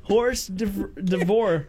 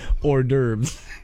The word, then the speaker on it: hors d'oeuvres: Horse divorce. – Get me out of here. – What? I don't know what you're laughing at because that's not a word, okay? – It is pronounced hors d'oeuvres.